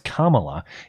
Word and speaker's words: Kamala: Bernie Weatherman says, dang it, Blonde, it's pronounced Kamala. Easy to remember because it sounds like Cobra-la.